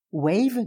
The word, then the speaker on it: Weiv: Wave, W-E-I-V, se prononce comme Wave, W-A-V-E, la vague en anglais, et l'anagramme de View, la vue, le regard.